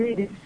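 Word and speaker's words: Írisz: E é isso mesmo que pronuncia, né? Apesar desse Z aqui no finalzinho. – É isso mesmo, Íris.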